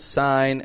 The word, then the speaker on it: sign: S-I-G-N. People pronounce sign as if the G is strong. It's a silent G. Sign. Sign above the line. The I in sign, same as the I in line. Sign above the line.